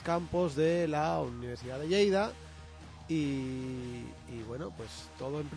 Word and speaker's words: Lleida: lo he dicho bien, Lleida. – Lo has dicho bien por una vez, oh, sí señor.